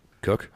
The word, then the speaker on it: Koch: I'm assuming it's Koch. So that's what I'm going to go with. – Cook.